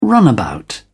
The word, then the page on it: runabout: British English